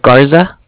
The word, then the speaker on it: Garza: Garza. – Can you spell that, please? – G-A-R-Z-A. Yeah, Z-A.